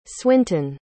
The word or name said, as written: swintun